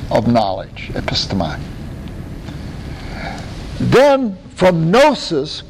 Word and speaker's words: gnosis: Now that's gnosis or gnosis. There's two ways to pronounce it. Usually English people say gnosis. But it's spelled G-N-O. Gnosis, okay? Gnosis. Now that is where I get it.